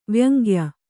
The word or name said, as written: ವ್ಯಂಗ್ಯ